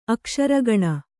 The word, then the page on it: ಅಕ್ಷರಗಣ: akṣaragaṇa